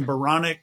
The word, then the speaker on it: Baronic: So I did a little bit of thinking and I thought my last name Baronic rhymes with bionic.